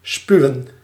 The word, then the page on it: vomir: France (Paris); [vɔ.miʁ]